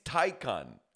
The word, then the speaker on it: Taycan: I have to ask you, how do you pronounce this? Taycan? – I think it's Taycan. – So it could be either. – We looked it up before this. It is Taycan.